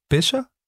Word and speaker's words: Becher: Øh, nu har jeg i hvert fald lært, hvordan man udtaler Becher.